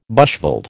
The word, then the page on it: bushveld: American English